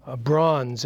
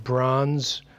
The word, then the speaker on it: Brauns: On the notes there, Chris Bronze in his book, Unpacking Forgiveness. I meant to bring it with me today, but I would recommend that you get that book if you want. Chris Bronze, that's spelled B-R-A-U-N-S.